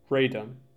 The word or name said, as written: Radom